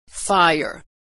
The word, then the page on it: fire: American English